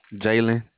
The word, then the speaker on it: Jalen: Jalen. – What's your name? – Jalen. – Can you spell that? – J-A-L-E-N.